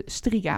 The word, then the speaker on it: Striga: Maar dat gaat over de Striga. Volgens mij is dat verhaal nummer één. Zeg ik dat goed? volgens mij wel.